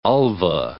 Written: ulva

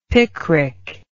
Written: picric

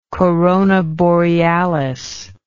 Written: Corona Borealis

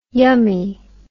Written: yummie

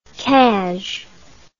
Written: cazh

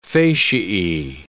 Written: fasciae

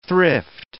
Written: thrift

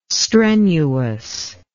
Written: strenuous

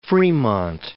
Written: Fremont

